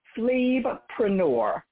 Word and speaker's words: PhlebPreneur: It is PhlebPreneur. So phlebotomist, entrepreneur. So, you know, you got mompreneur.